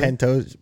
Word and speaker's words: Pantoja: Pantoja, man, just I keep saying his name wrong, right? That's it. What is that name? Pantoja? What did I say? – Pantoja. – Pantoja. – Pantoja. – Pantoja. – Pantoja. Okay. Pantoja.